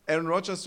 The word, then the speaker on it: Aaron Rodgers: ähm, Aaron Rodgers, du hast es richtig gesagt, Aaron Rodgers äh, verkündet, mal, verkündet mal wieder seinen Abschied.